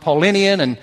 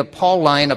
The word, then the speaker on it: Pauline: And I checked this week because I've heard it pronounced Paulinian and Pauline, but it's actually the Pauline apostleship.